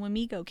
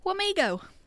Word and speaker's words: Wamego: It's about the Columbian Theater in Wamego, Kansas. Wamego.